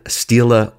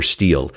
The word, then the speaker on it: stela: stela or stele. You know what a stela or stele is, both accepted pronunciations.